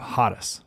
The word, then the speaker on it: charis: So the Greek word that Paul uses is charis. It's spelled C-H-A-R-I-S, charis.